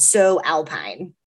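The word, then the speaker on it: Sew Alpine: We'll be talking to Hannah Trim, the founder of a Colorado-based cottage company called So Alpine. – Hannah defines the name of her one-woman show by saying So Alpine is a pun, because it's spelled S-E-W.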